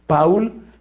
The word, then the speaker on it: Paul: Ese hombre se llama Paul, o sea, Paul pronuncian en francés, Paul Claudel.